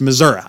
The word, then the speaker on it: Missouri: Yes, Missouri.